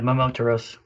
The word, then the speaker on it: Momotaros: And, you know, like, my favorite is Momotaros. Did I say that right? – Yeah, Momotaros.